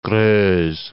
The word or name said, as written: Greuze